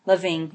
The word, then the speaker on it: Lavigne: Avril Lavigne. How do you say it? Avril. How do you say her last name? – Lavigne.